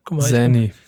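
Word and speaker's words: Xanny: Wie spricht man die Droge richtig aus? – Zanny. – Zanny.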